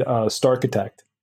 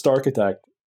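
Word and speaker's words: starchitect: Because even, you know, it was always typed out as Starkitect. And then I actually started hearing people saying Star Architect. And I'm like, no, you're saying it wrong.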